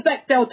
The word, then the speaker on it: Bechdel: saying about this show, it's I have checked, it does pass the Bechdel test, and it is the Bechdel test, Robin. I checked the pronunciation, because we have so much strong female Bechdel.